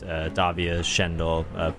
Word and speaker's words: Davia Schendel: We're playing with Marika, Christine, and a band on tour, June Swoon, and another local act, Davia Schendel. Pardon the pronunciation. Great lineup. – No, that was correct.